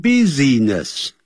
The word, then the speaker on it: business: N-O-N-E none of your business B-U-S-I-N-E-S-S那个不要念成 business 叫 business 那个 I 是不发音的